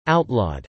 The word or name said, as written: outlawed